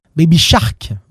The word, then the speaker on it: Baby Shark: C'est qu'aujourd'hui, on va parler de Baby Shape, Baby Shake, Baby Shark, on va le prononcer correctement.